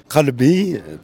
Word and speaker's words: qalbi: Oui, qalbi, parce que c'est un qa, ça veut dire mon cœur. Mais comme elle n'arrivait pas à prononcer le mot qa, ce qui est difficile pour un français, comme la khanta, ou le khe, ou le je, le je, il y a plein de sons comme ça, pas évident.